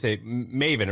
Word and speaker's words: Maven: So Maven, how do you say, Maven? It rhymes with like Raven. – yeah, there you go. – With an M.